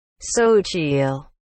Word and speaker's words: Xochitl: Se, se tulee kuulumaan teille nyt. So chill.